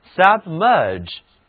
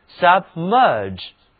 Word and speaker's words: submerge: 这里字母 GE 组合读作 G，嘴唇要稍微的向外凸起 G，这个词的重音在后面的音节上，请跟我读 submerge submerge。